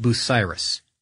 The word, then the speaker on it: Bucyrus: And then to kind of complete that thought, Zach from Wisconsin wrote in and said that, his uncle works for, the company, it's pronounced Bucyrus, which is B-U-C-Y-R-U-S, Bucyrus.